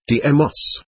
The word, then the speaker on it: Deimos: I believe the ancient language term would be Deimos phonetically, Deimos.